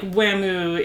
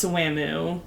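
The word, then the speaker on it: Wamuu: And like Whamu is like, it's not Wham, it's Whamu.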